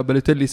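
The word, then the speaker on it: Balotelli: بلوتيلي بالوتيلي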